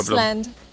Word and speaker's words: island: Palabras que tal vez pronuncias mal. Plumber. Plumber. – Bombero. Plumber. – fontanero. Island. Island.